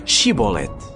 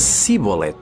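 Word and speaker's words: Shibboleth: Se ele respondesse não, obrigavam-no a dizer Shibolet. Mas se ele dissesse Sibolet, porque não era capaz de pronunciar corretamente a palavra, então prendiam-no e matavam-no ali mesmo.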